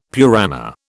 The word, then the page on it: Purana: British English